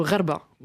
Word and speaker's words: Gharba: Alors il y a donc effectivement deux grandes parties, alors Ogra et Khorba, je ne sais pas si je le prononce correctement, mais vous allez me corriger, allez-y. – Ogra ou Gharba. – Bon, Gharba. Voilà, très bien.